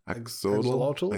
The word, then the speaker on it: axolotl: Yeah, how do you pronounce that? Axolot. – Axolot? – Axolotl? I think it's axolotl. – Is there no... interesting. – Expecting. Yeah, it's called an axolotl.